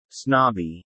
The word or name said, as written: snobby